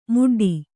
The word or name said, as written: ಮುಡ್ಡಿ